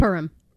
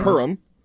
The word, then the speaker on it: Purim: And Purim. – Right. Purim.